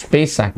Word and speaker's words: Pesach: Okay, it's pronounced as Pesach.